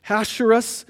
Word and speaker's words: Ahasuerus: Ahasuerus, which is also, his Greek name is Xerxes.